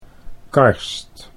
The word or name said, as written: Karst